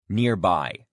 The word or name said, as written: nearby